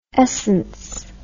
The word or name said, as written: escence